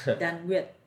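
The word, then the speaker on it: đàn nguyệt: You play the Dan Nguyen, right? – Yeah. – I don't know if I'm saying that right. It's probably not. – No, that's right. – It's Dan Nguyen. – Yeah. Dan Nguyen.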